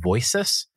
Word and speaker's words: Voysis: It's a company called Voices, which is V-O-Y-S-I-S. That's how it's spelled. – V-O-Y-S-I-S.